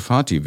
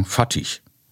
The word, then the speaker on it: Fatih: Das deutsche Fatih wäre beim Namen des berühmtesten deutschen Filmregisseurs schon die richtige Aussprache. Oberschlaue hängen ein Ch an und sagen Fatih. Solch einen Namen gibt es nach Auskunft des Turkologischen Instituts der Freien Universität Berlin im Türkischen gar nicht.